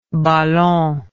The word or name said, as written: balon